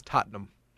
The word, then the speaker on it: Tottenham: And uh, the uh, pronunciation is Tottenham.